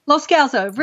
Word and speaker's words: Loscalzo: Loscalzo, really close.